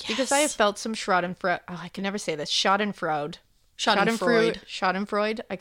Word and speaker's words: Schadenfreude: I don't Schadenfreude. – Know. Schadenfreude. There you go. Schadenfreude.